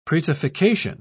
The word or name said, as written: prettification